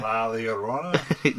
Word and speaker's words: La Llorona: Say it one time for the fan. – La Llorona. – There you go. You said it correctly.